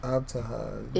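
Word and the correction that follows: Ibtihaj: It looks like Ibtahaj. – That's how you say it.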